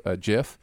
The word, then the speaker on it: GIF: Uh, trigger warning: the narrator, pre- you know, prefers a soft uh, uh G pronunciation of GIF, uh, and then the comments.